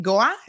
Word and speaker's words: Goa: So you grew up in Goa.